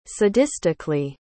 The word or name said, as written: sadistically